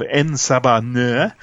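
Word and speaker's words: En Sabah Nur: I like the idea of En-Sabah-Nur."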